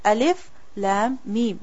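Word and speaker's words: Alif Lam Mim: You don't say a, you don't say l, you don't say m. How do you say it? Alif, Lam, Mim, with the name of the letter.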